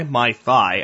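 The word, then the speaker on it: Mai Thai: Mai Tai, actually. Tai, how do you say it? T-H-A-I, M-A-I-T-H-A-I, coffee.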